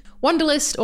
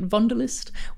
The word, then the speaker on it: Wunderlist: Wanderlust, or I guess I should be pronouncing it Vonderlist, was the first German startup to raise funding from Sequoia, and it really kicked off the Berlin startup scene.